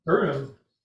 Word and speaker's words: Purim: Purim, actually is how you say it.